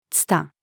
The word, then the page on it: 蔦: female